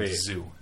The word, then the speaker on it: Zhu: Z-H-U, right? – Okay. – So, it's pronounced Zhu, but my friend, for Chinese people... – Oh, God. – For Chinese people, Zhu in Mandarin is pronounced Zhu. – Mm-hmm. – So, my friend was like, hey, you listen to Zhu? And I'm like... So, I was really confused the next day because I had no idea who he was talking about, and then I realized it was Zhu.